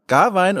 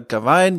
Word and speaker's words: Gawain: Ähm, ich nenne meine Charaktere auch immer Garwein oder Gawain, je nachdem, wie man ihn aussprechen möchte.